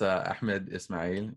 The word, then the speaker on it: Ahmed Ismail: It's Ahmed Ismail, and thank you for having me, Jenny.